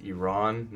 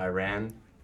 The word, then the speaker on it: Iran: One slight correction I'd like to make is that it's actually pronounced Iran, not Iran.